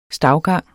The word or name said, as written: stavgang